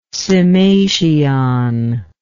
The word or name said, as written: cymation